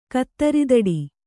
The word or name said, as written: ಕತ್ತರಿದಡಿ